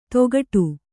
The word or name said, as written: ತೊಗಡು